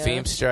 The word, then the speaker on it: Feimster: Feimster, I said it. – Uh-huh. – Said it right.